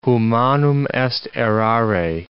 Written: humanum est errare

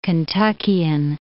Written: Kentuckian

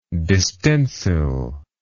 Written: distensile

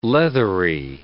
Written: leathery

